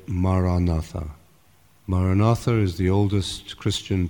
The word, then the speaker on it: Maranatha: And if you choose this word, say it has four syllables, ma, ra, na, tha.